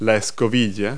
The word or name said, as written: whisk